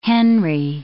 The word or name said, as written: henry